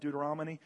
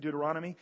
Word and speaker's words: Deuteronomy: If you go all the way back into the Old Testament, there's this book, and it's hard to pronounce because everybody calls it Deuteronomy, and it's actually Deuteronomy.